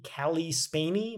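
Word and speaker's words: Cailee Spaeny: There's a quote from the GQ article from the woman who plays Priscilla in that movie, Callie Spaney.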